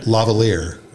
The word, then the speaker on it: lavalier: Lavalier? – Lavalier. – It's like the whole tiramisu argument again. – Did I say it wrong? – I don't know. I've never heard it. – A lavalier. No, it's a lav mic, but it's a lavalier. Am I saying it right? Lavalier?